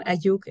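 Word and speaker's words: Ayuuk: la pronunciación es Mije en español, Ayuk en mi lengua,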